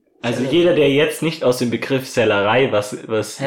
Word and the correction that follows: Sellerie: Sellerie gibt's als Gemüse.